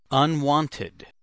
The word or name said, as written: unwanted